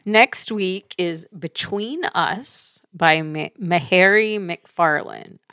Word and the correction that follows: Mhairi: I don't know how you actually pronounce it. It's Mhairi. Mm-hmm.